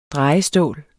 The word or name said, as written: drejestål